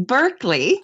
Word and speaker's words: Berkeley: Well, no, it would be Berkeley. – Ah. – You see Berkeley. That's so funny. And you pronounced draft exactly the right way, because it sounds draft. Much more romantic when you say draw. – It's so funny. – I'm going to say fast draw. – I did with the Berkeley. That's in Britain. Berkeley is pronounced Berkeley. So it's actually like an English thing. So it's the Americans, as usual, who pronounce it wrong.